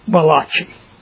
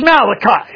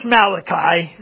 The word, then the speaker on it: Malachi: Now, I know better than that, but, I mean, it looks like, if you read it phonetically in the English, it looks like the prophet Malachi. And if that ain't Italian, I don't know what. But it's Malachi, okay? It's Malachi. It's not Malachi, but it's Malachi.